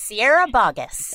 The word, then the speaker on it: Sierra Boggess: Sophie said Sierra Boggess. – And Sierra? – That is correct. And you pronounced my name correctly, Sophie.